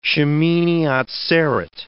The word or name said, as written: Shemini Atzereth